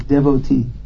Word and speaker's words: devotee: Well, the actual pronunciation is devotee. Srila Prabhupada, from devote, the verb devote, he changed it to devotee.